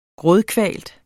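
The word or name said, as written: GRÅDKVALT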